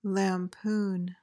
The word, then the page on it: lampoon: lam-POON